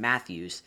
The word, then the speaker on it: Matthews: They shut down Marner's and Matthew. Marner and Matthews, butchered that one.